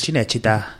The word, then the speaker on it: Cinecittà: El estudio Cinecittà, que es Cinecittà.